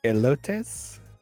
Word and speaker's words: elotes: Elotes? Does that sound right? – I love it. It wasn't me this time. – Yeah, no, I think that is how you say it.